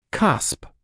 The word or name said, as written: cusp